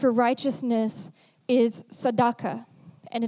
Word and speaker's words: tzedakah: T-S-E, D-E-Q-A-H. Tzedakah. Yeah, exactly how it sounds.